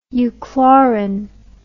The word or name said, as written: euchlorin